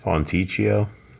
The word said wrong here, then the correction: Fontecchio: Fontecchio. – That's right.